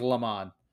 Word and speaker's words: Le Mans: It has an S at the end of it, but I'm... – Depends on your accent, I think. I guess. – Fairly certain it's pronounced Le Mans.